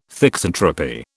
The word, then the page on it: thixotropy: British English